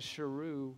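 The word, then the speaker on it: Sheru: He's been saying Saru, and his name is Sharu, which means lion.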